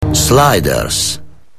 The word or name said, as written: Sliders